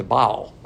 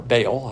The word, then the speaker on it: Baal: But the Lord said, no, there's 7,000 that have refused to bend the knee to Baal, or Baal, however you want to pronounce it.